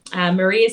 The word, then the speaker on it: Maree: Maree is spelled with two E's, M-A-R-E-E.